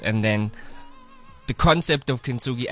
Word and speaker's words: Kintsugi: How do I say it? Is it Kintsugi? – Yeah. – Kintsugi, right? – Well, that's pretty interesting.